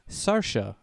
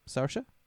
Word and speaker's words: Ciarcia: Fruit vendor is played by John Chacha Sarsha, I think is how you say his last name. C-I-A-R-C-I-A? Sarsha? – Sure.